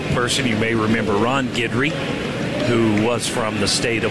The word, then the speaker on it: Guidry: And this year when we get here, look at the pronunciation guide, and next to his name it says G-I-D-G-E-R-E-E, as if you should be pronouncing it Guidry.